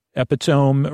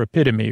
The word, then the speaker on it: epitome: And a lot of times people get made fun of because they pronounce this the wrong way. Epitome or epitome, right? And I've said it, I'm sure I said epitome on the podcast before.